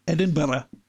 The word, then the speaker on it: Edinburgh: Wie spricht man das aus? Edinburgh? Okay. – Edinburgh. – Alles klar, danke.